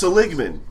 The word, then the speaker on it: Seligman: Seligman.